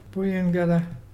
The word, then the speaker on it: Buyungarra: Booyangada is turtle, and it's spelt B-U-Y-U-N-G-A-R-R-A. Can you say Booyangada?